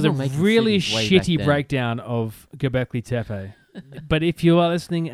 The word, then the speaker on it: Göbekli Tepe: Like we're finding new stars and new galaxies and we've just found Göbekli Tepe.